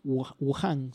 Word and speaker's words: Wuhan: En la ciudad china de Wuhan, se llama así, con un H en el medio, Wuhan se pronunciará. Juan, no sé.